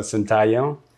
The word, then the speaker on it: Sintayehu: um, yeah, so Sintayehu.